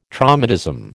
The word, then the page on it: traumatism: American English